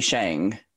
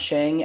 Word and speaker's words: Sheng: So for me, I say Sheng. It's pronounced Sheng as in Li Sheng from the Disney Mulan.